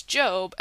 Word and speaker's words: GOB: it's spelled G-O-B, so it seems like it should be pronounced Gob, but it's Job, and so it's funny to me that people just, like, can't get this man's name right.